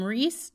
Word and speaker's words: Rhys: They named him Rhys James Carney, and that's spelled R H Y S.